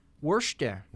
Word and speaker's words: Worcester: Worcester. – Is that how you pronounce that? – Worcester, Mass.